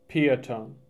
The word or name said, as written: Peotone